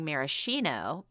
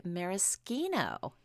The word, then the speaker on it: maraschino: And final thing, bonus, your brother taught us the correct pronunciation of the word. All my life, I've been saying maraschino, but apparently it's actually maraschino.